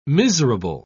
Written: miserable

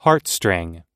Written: heartstring

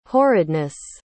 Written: horridness

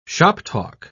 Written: shoptalk

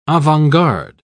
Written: avant-garde

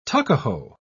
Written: tuckahoe

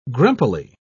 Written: grumpily

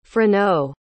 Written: freneau

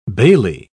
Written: bailey

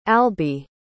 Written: alby

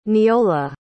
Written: neola

